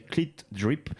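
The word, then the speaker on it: CLT DRP: Ça s'écrit CLT, plus loin DRP. Il faut le prononcer Clit Drip.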